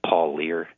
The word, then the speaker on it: Parlier: And I think the Ellis's last name is pronounced Paul Lear. It's spelled P-A-R-L-I-E-R.